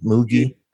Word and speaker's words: Moogie: Did I say that right, DJ? Moogie? – You did, and I think she was also Don Adams from Get Smart's daughter.